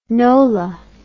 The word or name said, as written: Nola